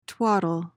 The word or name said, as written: twattle